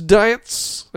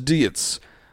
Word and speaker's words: Dietz: It's like diets, but spelled with a Z. – Dietz. – I think it's Dietz.